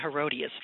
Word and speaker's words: Herodias: Am I saying her name right? – That's what I've always said, Herodias.